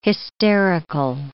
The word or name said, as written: hysterical